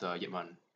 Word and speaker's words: Ip Man: I'd say that it actually kind of started with Ip Man, because that's the proper pronunciation. – That's the proper Chinese way of saying it.